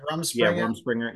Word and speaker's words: Rumspringa: You'd have a lot of fun up there during, what is it, Rumspringa? Rumspringa? Yeah. Am I saying it right? Rumspringer. – Yeah, where they can go wild, you know, whatever, to get it out of their system.